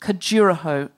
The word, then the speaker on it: Khajuraho: And Ned, have I pronounced Khajuraho correctly? – Completely. I know how to pronounce it.